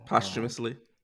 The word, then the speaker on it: posthumously: But for him to be able to, is it posthumously? I don't know how to say it. But, you know. – Posthumously. – Posthumously. Thank you.